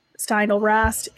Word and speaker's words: Steindl-Rast: So Happiness by Matthew Ricard and Gratefulness by Brother David Steinrast. I just did not pronounce his name properly. Those are really good. Matthew Ricard is a Buddhist monk. David Steindl-Rast is a Benedictine monk.